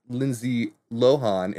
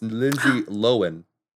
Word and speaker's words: Lindsay Lohan: So in this little short series, she came in and she said, oh, it's actually not pronounced Lindsay Lohan. It's pronounced Lindsay Lohan.